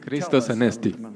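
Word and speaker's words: Christos anesti (Χριστός ανέστη): Christos anesti.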